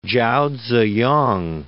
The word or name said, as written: Chao Tzu-yang